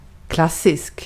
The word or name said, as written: klassisk